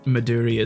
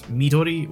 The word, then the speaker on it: Midori: And I hate to be the one to point it out, Maduri is not the right pronunciation. – Damn it! – Because it's a Japanese drink, so it's Midori, which is green, because it's, I think, watermelon flavoured.